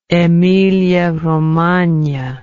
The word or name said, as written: emilia romagna